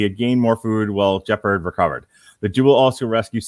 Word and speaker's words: Jeppard: So Jeppard, which rhymes with leopard.